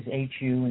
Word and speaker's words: HU: In Ekankar, they use Om, but the one that they prefer is H-U, and it's pronounced Hu.